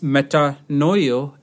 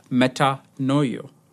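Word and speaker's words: metanoia: The word repentance is pronounced as metanoio in Greek, metanoio.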